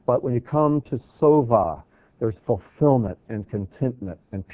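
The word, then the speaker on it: sova (שבע): But if you pronounce the same word, note the letters are identical, if you pronounce it sova, it means to be satisfied or fulfilled.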